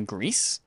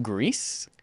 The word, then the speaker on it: Gris: Greece. G-R-I-S. It's pronounced Greece, not Gree, like I was for the last month and a half.